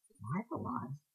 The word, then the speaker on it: Live A Live: I think Live a Live is the correct pronunciation, though.